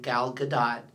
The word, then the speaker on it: Gadot: Well, Gal Gadot, who I just recently discovered that is how you pronounce her name, Gal Gadot. It's according to her, looked it up.